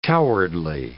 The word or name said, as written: cowardly